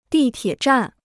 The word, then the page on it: 地铁站: dì tiě zhàn